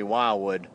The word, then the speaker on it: Wildwood: Keith and I will be passing through lovely Wildwood. It's spelled with two D's but pronounced with one.